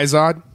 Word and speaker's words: Izod: Izod.